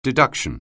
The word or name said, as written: deduction